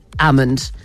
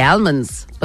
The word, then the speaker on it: almond: I would have said, I didn't know until like a couple of years ago that almond. I used to say almonds, but most people do say almond because it is spelled with an L, but it's a silent L apparently.